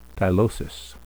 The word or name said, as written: tylosis